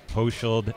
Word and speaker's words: Hochschild: And my Twitter is Rob Hochschild, just at Rob Hochschild.